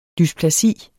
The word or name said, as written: dysplasi